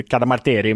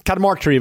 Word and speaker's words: Cadamarteri: Och här när jag har gjort min research så uttalet varierar, det är ju italienskt egentligen ju, Cadamarteri, men, men uttalet varierar ju på alla de här engelska kommentatorerna. Vissa säger Cadamarteri och några säger Cadamart, alltså det är, Cadamarteri verkar vara vanligast i alla fall.